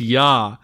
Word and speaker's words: YA: It's Yah. YA. – I just say YA. – Young adult. It's pronounced Yah. I'm telling you.